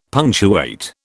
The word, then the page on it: punctuate: British English